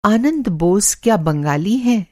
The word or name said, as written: बंगाली